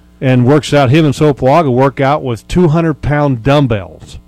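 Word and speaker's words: Sopoaga: Yeah, the two strongest guys and two of the stronger guys, probably the strongest guys in the National Football League is Sopawaga. Did I say that right? – Isaac Sopawaga, right.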